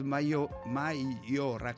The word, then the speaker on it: Maiora: Ad Maiora, come si legge? Ad Maiora. – Maiora, ho letto bene allora.